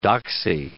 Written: doxie

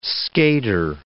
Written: skater

